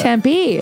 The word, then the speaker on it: Tempe: The Tempe. That's how you say it, right? – Yeah, they really get into that. – Tempe. It's Native American, I believe. – Yeah, it's indigenous. Tempe. It's Tempe. And then June 20th through 22nd, Washington Dick Come at the Dick Come Improv.